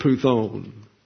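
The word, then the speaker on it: puthon: I want to show you this word puthone. That's P-U-T-H-O-N, puthone. And the accent is on the U here.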